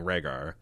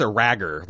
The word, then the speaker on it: Rhaegar: just so you know, in case you missed the irony of her mispronouncing Rhaegar. – I did miss it. What are you talking about? – Well, she's pronounced a ragger, like it's like horribly mispronounced, and it's spelled in the subtitles as R-A-G-G-E-R, but we're supposed to understand that it's Prince Rhaegar.